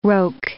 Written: roque

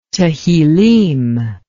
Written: Tehillim

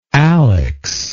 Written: alex

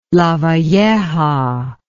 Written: Lavalleja

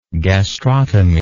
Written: gastrotomy

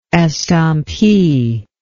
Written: estampie

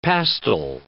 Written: pastil

